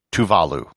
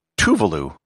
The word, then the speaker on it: Tuvalu: It's pronounced Tuvalu, with the accent on the second syllable, not Tuvalu, with no syllable accented.